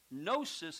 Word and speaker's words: gnosis: And the word comes, gnosis is the word, G-N-O-S-I-S, gnosis.